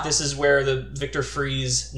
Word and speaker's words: Fries: I like that it's spelled like fries. I always read it as fries in my head. – F-R-I-E-S.